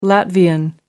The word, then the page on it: latvian: American English